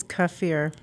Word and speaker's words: kefir: And am I saying it right? It's kefir, right? Not kefir. – It's kefir. – Kefir. – Yeah.